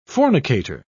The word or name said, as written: fornicator